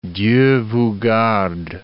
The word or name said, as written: Dieu vous garde